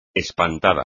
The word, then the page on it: espantada: espagnol